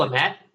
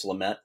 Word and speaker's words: Lamet: I'm butchering that pronunciation probably. Denelson, I think, is actually. – I think it's Lamet. – It's Lamet. Yeah, I got all the stress wrong.